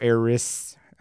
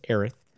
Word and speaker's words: Aerith: Final Fantasy VII Aerith or Aeris, however you pronounce it. I go with the Japanese version, which is Aerith.